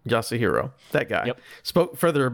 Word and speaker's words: Yasuhiro: What was that? Yoshiro Kitao? – Yasuhiro. – Yasuhiro. – Think of him in like two letter syllables.